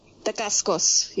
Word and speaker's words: Dacascos: and this was directed by Mark De DeCasa De, De Cascos. If I'm pronouncing that right, I don't care. Dacascos, yeah.